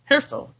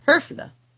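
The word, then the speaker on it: Höfle: Now, this is really pronounced Herfel, I guess, Herfle, Herfle, beyond my ability to say properly. But it is spelled, the name is spelled H-O-E-F-L-E. It's that umlaut O.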